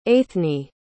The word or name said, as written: aithne